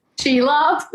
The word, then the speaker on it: Shelob: Shelob.